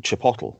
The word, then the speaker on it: Chipotle: "chipotle," right? – It is Chipotle, you fucking idiot.